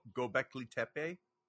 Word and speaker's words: Göbekli Tepe: Isn't that how somebody discovered Göbekli Tepe? – Yeah, so that's how you pronounce it.